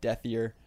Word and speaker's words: Dethier: D-Y-L-A-N underscore D-E-T-H-I-E-R. So it looks like Dethier, pronounced Dethier.